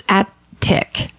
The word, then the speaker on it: attic: Well, it's attic. – Attic. – There's no D.